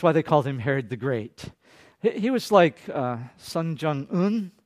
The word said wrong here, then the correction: Kim Jong-un: Kim Jong-un, okay?